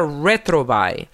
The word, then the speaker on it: Retrouvaille: ayudar a parejas en alto conflicto se llama Retrovail, que es una palabra en francés que se escribe R-E-T-R-O-U-V-A-I-L-L-E.